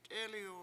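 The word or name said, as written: t'élɨw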